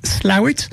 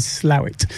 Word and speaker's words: Slaithwaite: Well, it's pronounced Slawit, but if I spelt it out for you, S-L-A-I-T-H-W-A-I-T-E, you would say Slaithwaite. – Slaithwaite. – But the local pronunciation is Slawit.